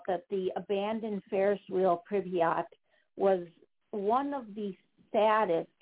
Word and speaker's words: Pripyat: Pripyat? – I believe it's Pripyat. Oh, Pripyat. – Pripyat. – Pripyat. Okay.